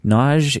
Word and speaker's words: Nagy: Nage is pronounced Nage. It is spelled N-A-G-Y, like Nagy or Nagy, but it's pronounced Nage.